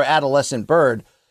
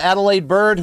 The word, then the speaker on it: Adalaide Byrd: Yet if there's a title fight in Texas, old Larry's there, Luke, from, you know, I want to call her because Danny Jacobs once famously mispronounced this on my podcast. I wanted to call her Adolescent Bird.